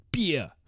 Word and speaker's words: Bier: Which I made sure to say that Bier is how you pronounce it in German. Bier!